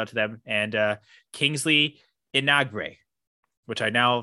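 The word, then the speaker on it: Enagbare: It's pronounced Enagbre. Enagbre. – That sounds good.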